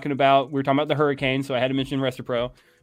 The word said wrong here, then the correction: Restrepo: for you, even though he Restrepo, but yeah, I got you.